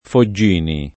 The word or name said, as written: Foggini